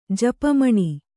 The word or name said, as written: ಜಪಮಣಿ